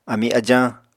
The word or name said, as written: ami ajañ